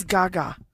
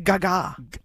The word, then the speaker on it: gaga: What is Gaga? Or is it Gaga? – It's not the second. There's no emphasis on the second vowel.